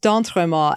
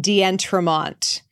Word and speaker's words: d'Entremont: That is pronounced d'entremont, and you don't pronounce the last two letters of it at all. It's just there to weed out telemarketers.